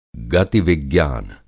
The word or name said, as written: गति-विज्ञान